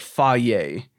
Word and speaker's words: Fage: I think, so it's spelled F-A-G-E, but if I understand correctly, it's pronounced something like Faye.